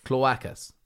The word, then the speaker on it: cloacas: How do you pronounce that? – It's pronounced cloacas.